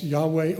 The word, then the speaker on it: Yahweh: There are four Hebrew consonants, YHWH, pronounced Yahweh or some Jehovah.